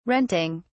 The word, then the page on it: renting: British English